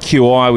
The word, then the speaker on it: Qi: So it's called Qi, isn't it? That's the right pronunciation, because I think initially, with it being spelled QI, we wonder if it was the Qi wireless standard. So Qi is the correct pronunciation for the wireless charging.